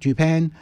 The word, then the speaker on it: Dupain: And we said goodbye to, on this day in 1992, Max Dupain, I think that's how it's pronounced, Dupain.